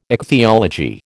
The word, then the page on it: ichthyology: American English